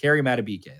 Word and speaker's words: Madubuike: Madubuike. Him and McCary Madubuike. That's the right pronunciation.